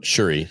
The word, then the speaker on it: Shuri: That in my, is it Shuri? I feel like I always say her name wrong. – Shuri. Yep.